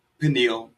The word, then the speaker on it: Pineal: I'm being joined by the, hopefully I'm pronouncing this right, Pineal? – I pronounce it Pineal, but...